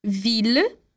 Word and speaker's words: ville: No entanto, para as palavras mille, ville e tranquille, a gente vai pronunciar os dois L's com o som de L, normal. Então, lembra lá: ville, mille.